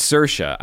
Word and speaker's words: Saoirse: It's Saoirse. I know how to pronounce that. Saoirse Ronan.